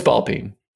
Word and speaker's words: ball peen: Is it ball peen? Am I mispronouncing that? – No, it's ball peen.